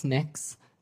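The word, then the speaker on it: Mx: It's pronounced mix.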